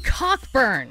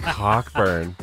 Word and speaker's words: Cockburn: Cockburn. – You got a cook. – Nope. – It says here it's pronounced a cook.